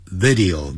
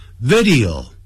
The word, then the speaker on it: video: 他就针对这个 playing video games， video，哎，不要念成 video、啊、不是 video，他是三个音节 video，叫 video，就像收音机 radio，不要念成 radio，不是 radio，叫 radio video，好，录影的影像的叫 video games，就是游戏，也就指的是电动游戏电玩，我们常常说的。